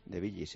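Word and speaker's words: de Villis: De Bilis, sí. – ¿Y por qué lo pronuncias de Bilis? – Bueno, pues porque se pronuncia así. Ah, vale. La E en francés no existe.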